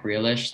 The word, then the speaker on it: Grealish: I have De Bruyne also, and Jack Grealish. That's how you say it, right? – Yeah.